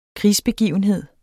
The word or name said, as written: krigsbegivenhed